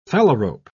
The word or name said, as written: phalarope